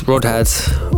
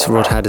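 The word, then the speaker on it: Rødhåd: This is Rodhead, or Redhead, depending on which pronunciation you prefer. I guess for the purposes of phonetics and ease of use, Rodhead is probably the one that most people use.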